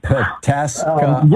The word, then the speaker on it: Pataskala: Tell me how to say this. – Pataskala. Yeah, so okay.